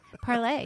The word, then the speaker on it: Parler: I've been on Parler. – Parlay, don't you know? – What? – It's like Emily and Perry. – No. – Yeah. – It's pronounced Parlay? – Yeah.